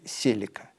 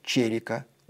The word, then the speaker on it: Celica: Не прекращаются споры, как правильно произносится ее имя. В европейской части России принято говорить «Селика». Дальневосточники за это ткнут клятых москалей розочкой, поскольку у них требуется говорить «Целика». В англоязычных странах произносят «Селика», ударение на «и». Даже есть соответствующая реклама с Эдди Мерфи. В Японии говорят «черика», ударение на «е».